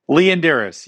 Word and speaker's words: Leondires: Mark, how do I pronounce that name? – Leon Dyrus. You were very close.